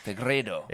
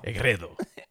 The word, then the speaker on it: Fegredo: I tried to say it all Fegredo. Ethnic. And, I try to sound like Freddy, the way he can say, you can roll your R's. Fegredo. – Fegredo.